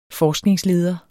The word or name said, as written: forskningsleder